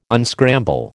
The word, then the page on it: unscramble: American English